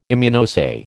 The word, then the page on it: Immunoassay: American English